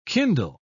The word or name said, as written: kindle